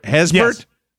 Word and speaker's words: Haysbert: Haysbert? – Yes.